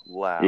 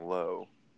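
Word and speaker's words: Lowe: It's pronounced low, or low, as in now, Lau. – Yeah, Brandon Lau. – So I would have been saying low.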